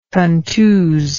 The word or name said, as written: contusive